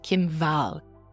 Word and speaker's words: Kim Wall: And then we have Kim Wall, okay? The W is more like a V, and that's her pronunciation of her name in Swedish.